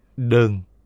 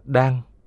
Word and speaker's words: đơn: Đơn hay tòa, sàn, ghế, giường, đọc đúng là đan, là chỗ ngồi của mỗi tăng nhân trong thiền đường.